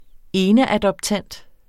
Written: eneadoptant

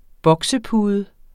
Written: boksepude

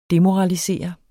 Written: DEMORALISERE

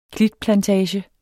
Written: klitplantage

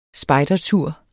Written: spejdertur

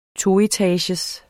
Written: toetagers

